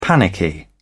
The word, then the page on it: panicky: British English